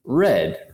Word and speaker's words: read: Uh, and then you have the past tense, which is red, right? It's pronounced like the color, um, but it's spelled the same way.